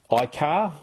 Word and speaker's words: iCaur: iCar, which is spelled I-C-A-U-R, but that's iCar is how they were pronouncing it.